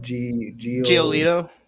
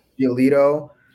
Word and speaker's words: Giolito: Giolito. – Giolito.